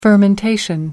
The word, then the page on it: fermentation: British English